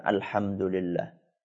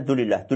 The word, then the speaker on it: alhamdulillah: Contoh misalnya ketika kita mengucapkan alhamdulillah, ya, banyak orang ketika membaca itu gak sempurna. Karena pengen cepat selesai, maka dia bacanya dulillah, dulillah, dulillah. Apa itu dulillah?